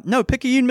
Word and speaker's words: Picayune: Picayune, Mississippi.